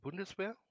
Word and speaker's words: Bundeswehr: Do you mean the Bundeswehr? – Yes, that's it. – Bundeswehr is like army. It's the main, I would call it army. – Well, Bundeswehr is the main German armed forces.